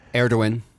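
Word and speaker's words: Erdoğan: Erdogan. – That's how you say it? – Yes.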